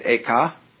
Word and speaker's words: Eka: The second was named Eka, I'm using the Japanese pronunciation, who was formerly a general of the army.